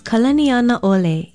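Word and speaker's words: Kalaniana Ole (Kalanianaʻole): Another beautiful Hawaiian name that is so often mispronounced is Kalaniana Ole. It is spelled K-A-L-A-N-I-A-N-A Okina O-L-E, and it means the chief without measure.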